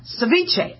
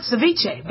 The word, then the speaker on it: ceviche: Ceviche. I've been calling it ceviche, but apparently in South America, the B is pronounced, or the V is pronounced like a B, all right? So it's ceviche.